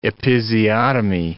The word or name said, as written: episiotomy